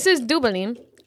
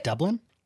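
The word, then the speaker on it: Duvalín: It's but spelled you like get, Dublin? – It's like D-U-V-A-L-I-N. Dublin. I just can't pronounce V's in Spanish.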